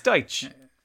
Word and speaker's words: Dyche: Yeah, fuck, I always forget how to pronounce his name. Dyche. It's Dyche.